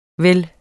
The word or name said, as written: vel